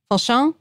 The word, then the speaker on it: Fauchon: Fauchon.